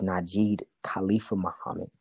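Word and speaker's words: Najid Khalipha Muhammad: And my Facebook name is Najid Khalifa Muhammad, NAJIDKHALIPHA Muhammad, MUHAMMAD.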